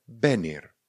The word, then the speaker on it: venir: Sloveso přijít se píše v e n i r. Vyslovujeme benir. Benir. Benir.